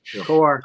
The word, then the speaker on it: Kowar: Is it Kowar or Kowar? Am I saying that right? – Kowar.